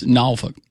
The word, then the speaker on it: Norfolk: Yeah, you say it by itself, so it's Norfolk.